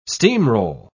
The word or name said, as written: steamroll